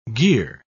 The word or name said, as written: gear